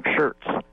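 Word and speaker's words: Scherz: I know there are inventors out there, so run, do not walk, to get Paul Schmertz. I'll spell it for you, S-C-H-E-R-Z. I guess I said it wrong. Shirts.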